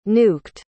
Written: nuked

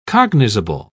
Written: cognizable

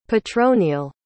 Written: petronille

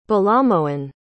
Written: balamohan

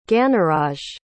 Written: ganaraj

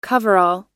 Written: coverall

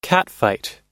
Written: catfight

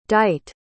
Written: dite

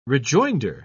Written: rejoinder

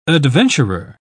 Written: adventurer

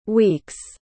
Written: week's

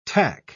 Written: tack

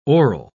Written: oral

